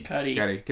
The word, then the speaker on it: Cudi: How do you pronounce is it Kid Cudi or Kid Cudi? – Cudi.